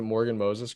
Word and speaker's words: Morgan Moses: Is it Morgan Moses, correct? – Yeah, that's right.